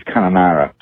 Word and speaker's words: Kununurra: Canawindra is pronounced Canawindra, and that other one in Western Australia that I can't even pronounce the way you pronounced it is Kununurra.